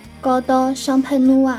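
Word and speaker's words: Coteaux Champenois: 今日教大家一下香槟区静态葡萄酒的发音：高多香喷努啊，高多香喷努啊，高多香喷努啊。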